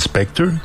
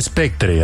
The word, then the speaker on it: Spectre: De hecho se escribe Spectre, así que Spectre.